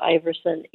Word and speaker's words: Iversen: And I will warn anybody that if you're searching for Angela Iversen Event Management, uh, Iversen is the Danish spelling.